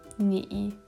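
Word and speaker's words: nie (你): Also, du, nie, dritte Betonung.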